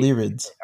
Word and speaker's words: Lyrids: Lyriad. How do you even spell that? Lyriad. – It's like lyrics, except instead of C, it's a D. – Shower. Too late. – Lyrids.